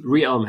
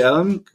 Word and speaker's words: ReaLM: Und durch, wie hast du es ausgesprochen? – Realm hätte ich gesagt. Realm, genau, Realm soll es quasi Siri auf Steroide geben.